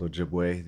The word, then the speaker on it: Ojibwe: So my tribe is called Ojibwe. The French mispronounced it and called us Chippewa.